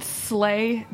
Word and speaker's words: Sley: Okay, it's called it's called Slay Deli. It's spelled S-L-E-Y.